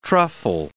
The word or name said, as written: truffle